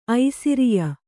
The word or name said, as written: ಐಸಿರಿಯ